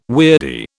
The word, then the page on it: weirdie: British English